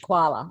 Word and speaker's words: Quala: So with the setting, first of all, is it pronounced Quala? How's the township? – I pronounce it Quala. I mean, yeah, it's a fictional town.